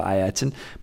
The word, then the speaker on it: Ayrton: In Brazil 1994, Murray once again tried to revert to the correct pronunciation of Ayrton, but the British public would have none of it and gave him abuse. That doesn't sound like the British public at all. So Murray switched back to Ayrton.